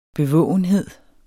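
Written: BEVÅGENHED

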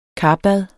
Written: karbad

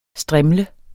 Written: strimle

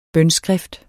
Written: bønskrift

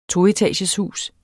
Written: toetageshus

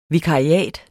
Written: vikariat

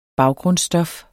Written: baggrundsstof

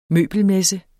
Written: møbelmesse

